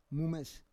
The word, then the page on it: mumes: Roon